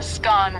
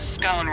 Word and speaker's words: scone: Both scone rhyming with gone and scone rhyming with bone are accepted pronunciations, though regional variations exist.